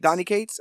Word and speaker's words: Donny Cates: Um, and then he got, you know, this uh, Danny Cates. Am I saying that right? – Donny Cates. – Donny Cates, yeah.